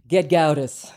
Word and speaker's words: Gedgaudas: Gedgaudas.